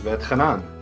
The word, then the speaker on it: Va'etchanan: Well, because today we are in, now if I pronounce this correctly, Va'etchanan, Nehemiah. – There it is, Va'etchanan.